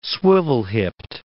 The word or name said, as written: swivel-hipped